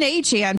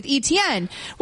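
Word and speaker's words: Achane: And so apparently, A-Chain, who was formerly, the artist formerly known as ETN, says that it's always actually been A-Chain, but we've always been pronouncing it wrong with ETN.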